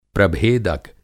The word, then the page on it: प्रभेदक: Hindi